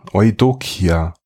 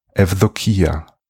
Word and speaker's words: Eudokia: Athenais wurde nun getauft und erhielt den Namen Eudokia, oder griechisch ausgesprochen Evdokia.